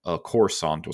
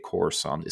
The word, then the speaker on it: Coruscant: and are basically the story of how Coruscant was retaken after the fall of the Empire. – It's pronounced Coruscant.